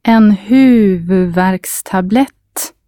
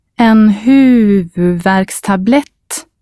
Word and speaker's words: en huvudvärkstablett: Repeat the word after you hear it again and try to stress the first and last syllable clearly. The first stressed syllable carries a long U sound and the word ends with a long T sound. The trick is to concentrate on these two stressed syllables and kind of rush through the middle bit.